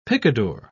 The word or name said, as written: picador